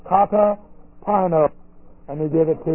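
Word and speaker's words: Kata pino (καταπίνω): Kata pino. And we'll know something about it. This is K-A, the prepositional prefix. K-A-T-A, that's a preposition. Prefix to the word pino, P-I-N, long O.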